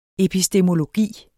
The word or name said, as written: epistemologi